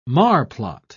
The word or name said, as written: marplot